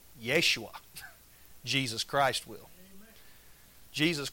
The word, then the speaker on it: Yeshua: If you know if you study the language of Hebrew, do you know how you say Jesus in Hebrew? You pronounce it Yeshua. What does that sound like? Sounds like Joshua. Because Joshua and Jesus in Hebrew are pronounced and spelt exactly the same.